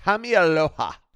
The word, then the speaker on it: Kamealoha: So this week we are catching up on Doogie Kamealoha. Is that how you say it? Doogie Kamealoha. – Kamealoha. – Doogie Kamealoha.